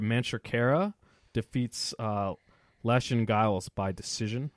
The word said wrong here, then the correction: Lachlan: You butchered his name. – How do you say Lachlan? – It? Lachlan. – Lachlan Giles? – Lachlan Giles. Okay.